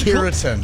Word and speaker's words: keratin: Ketamine? – Another mispronunciation. – What's keratin? – Keratin, there it is.